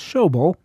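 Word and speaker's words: Schoebel: Before that, it was Art Hickman's orchestra, September 15, 1919, and Rose of Mandalay, written by Herbert Clair, C-L-A-A-R, which I'm going to guess is pronounced Clair, which reminds me that I have it on good authority that Elmer Schobel's name is pronounced Schobel, and not what I was pronouncing it on last week's show.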